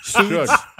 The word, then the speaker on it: Suge: S-U-G. – S-U-G, but it's short for sugar. It's Suge.